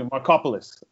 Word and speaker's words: Markopoulos: Because I think it was Ari, is it Macropolis, right? – I was just sitting here trying to pronounce the guy's last name in my head. That was the same pronunciation I came up with. – Excuse me, Markopoulos.